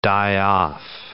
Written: die off

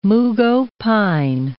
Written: mugho pine

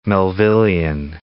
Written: Melvillean

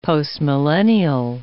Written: postmillennial

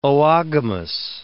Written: oogamous

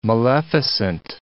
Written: maleficent

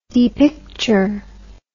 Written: depicture

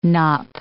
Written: knop

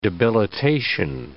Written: debilitation

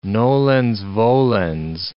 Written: nolens volens

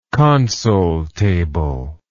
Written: console table

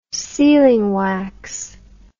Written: sealing wax

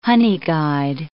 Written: honeyguide

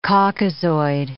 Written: Caucasoid

